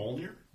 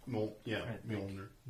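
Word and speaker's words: Mjölnir: Mjolnir? – Mjolnir.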